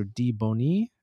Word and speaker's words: DeBonis: I don't know why I said it like that. That's probably not how you say it, but she's so. No, it's DeBonis and the B is capitalized. So DeBonis. Anyways, Carol, the character Carol Marcia.